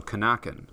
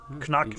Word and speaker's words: Knacken: Oh, so Knacken. – I don't think they've got silent letters in German. I'm not positive, though. But yeah, Knacklion and Knacken.